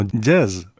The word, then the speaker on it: Gyezz: Ellos publican un disco titulado Giez, es como se escribe, con G-Y-E-Z-Z, aunque por lo visto se pronuncia algo así como jazz, en alusión, bueno, pues a una especie de hungarización de la palabra jazz. De hecho,